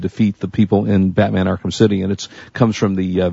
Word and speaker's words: Arkham City: Arkham City.